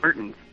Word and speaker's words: Mertens: It's Mertens.